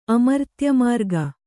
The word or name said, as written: ಅಮರ್ತ್ಯಮಾರ್ಗ